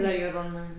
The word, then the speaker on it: La Llorona: La Llorona. – Yeah. Did she kill herself as well, or just the kids, and then she realized so what she'd done later? – Actually, the thing about La Llorona, it depends on what part you came from.